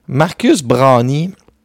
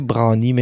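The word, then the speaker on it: Browne: Marcus Brownie. Moi, je l'appelle Marcus Brown, mais après ça, j'ai écouté, puis il faut prononcer Brownie, mais je l'appelais Marcus Brown.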